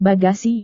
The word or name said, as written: bagasi